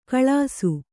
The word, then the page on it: ಕಲಿಸು: kannada